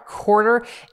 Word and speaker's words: Quartr: Q-U-A-R-T-R. – Yeah, there you go. Q-U-A-R-T-R, Quarter,